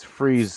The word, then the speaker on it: Fries: It's Freeze spelled like fries. So it's F-R-I-E-S.